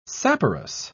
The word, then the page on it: saporous: British English